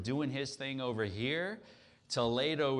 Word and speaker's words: Toledo: Now, it's about an hour, I think, outside of Madrid, and uh, there's a sister city in the US. We pronounce it Toledo, Ohio. Uh, but Toledo is actually a first century city.